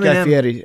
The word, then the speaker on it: Guy Fieri: I thought Insane Clown Posse, well, they reached the point, maybe not cool, but like Guy Fieri, they reached a point where it's like. – What? – Eminem. Guy Fieri, that's how you pronounce.